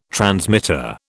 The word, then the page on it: transmitter: British English